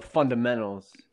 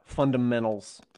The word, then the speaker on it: fundamentals: Yeah, they don't know the fundamentals. – of snowballs. – How Indiana was that? Fundamentals. – I don't. – Instead of pronouncing the T.